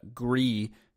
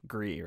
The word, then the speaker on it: Gris: Fallout seventy six, Red Dead Redemption, uh, Gree, is that how you pronounce that word? Yes. It's French. – Gris, right? – Correct.